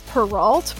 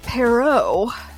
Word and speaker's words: Perrault: So I have always heard it said Charles Perrault, but it's come to my attention that that's anglicized and it's wrong. So it's actually Charles Perrault.